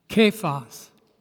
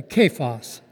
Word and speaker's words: Kephas: Peter's name in Aramaic is? Kephas. If you're going to say his name, say it correctly. Kephas. Your Aramaic is very poor.